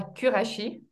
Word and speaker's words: Qurashi: Caroline du bas Kurashi, ça s'écrit Q-U-R-A-S-H-I, c'est très mhm.